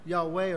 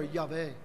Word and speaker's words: Yahweh: This all came about much later, but in the beginning, it had always been pronounced Yahweh or Yahweh.